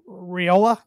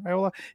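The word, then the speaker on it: Raiola: They do have Boy Wonder, as their fans are calling him, five-star freshman Dylan Riola, I believe is how you pronounce his last name, Riola.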